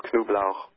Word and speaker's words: Knublauch: The name is in German pronounced Knoblauch.